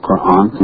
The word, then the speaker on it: Quran: I have to brush up on my pronunciation myself of Quran, long A.